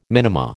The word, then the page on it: minima: American English